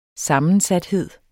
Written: sammensathed

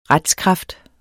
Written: retskraft

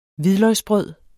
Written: hvidløgsbrød

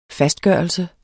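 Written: fastgørelse

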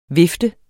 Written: vift

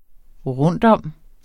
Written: rundtom

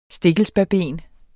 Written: stikkelsbærben

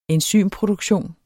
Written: enzymproduktion

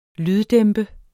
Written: lyddæmpe

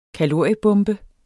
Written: kaloriebombe

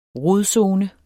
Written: rodzone

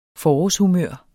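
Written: FORÅRSHUMØR

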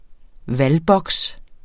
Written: valgboks

